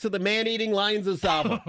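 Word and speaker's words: Tsavo: The Ghost in the Darkness was all based on the man-eating lions of Savo. You don't pronounce the T, I don't think.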